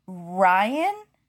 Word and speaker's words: Rein: Keith pronounces it like Ryan. – He does. – Or Ryan. – Ryan. – Ryan, kind Ryan. – Of. – Ryan. – So, like rind without a D. – So it sounds like Brian Ryan the entire episode. – Yeah. But it's spelled R-E-I-N, like rain.